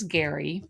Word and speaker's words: Gerry: So his name is Gary, but it's spelled Jerry, G-E-R-R-Y.